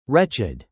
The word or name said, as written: wretched